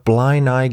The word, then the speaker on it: Blaenau: The case suggester Alex Strange got in touch to let me know that my pronunciations were pretty much spot on, and that I absolutely nailed the pronunciation of Blind Eye Gwent. Cheers, Alex. I wasn't sure I'd got that right, because it doesn't look like Blind Eye, B-L-A-E-N-A-U, but hey, that's the Welsh language for you.